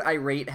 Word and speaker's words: irate: The word irate has the pronunciation on the second syllable. – I get that he's trying to rhyme it with mind state, uh-huh. But it's not irate. It's not like an iPhone. – Wait, how do you pronounce irate? – Irate. You say the rate part.